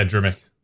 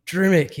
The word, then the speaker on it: Drmic: I really didn't want to pronounce the name wrong. Drimc? – Yeah, Drimc. – Drimc, yes.